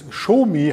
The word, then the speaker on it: Xiaomi: So wird es quasi geschrieben, aber ausgesprochen wird es Xiaomi, habe ich mich belehren lassen.